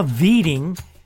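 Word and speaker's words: Widing: But McDonald believes Cook went beyond the limits of show business when he anglicized the name of Juha Markku Whiting, a Finland native born of Swedish parents. What could be more colorful than Juha Viting, as the name is pronounced?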